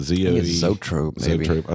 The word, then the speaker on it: zoetrope: Zoetrope.